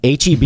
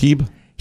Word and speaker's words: H-E-B: HEB is brilliant at guiding you through the stores. As a matter of fact, the regular HEB. – That's not pronounced Heeb? – Heeb. Heb. Heb. H-E-B. – Is H-E-B even?